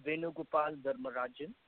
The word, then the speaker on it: Venu Gopal Dharmarajan: Yeah, my name is Venu Gopal Dharmarajan.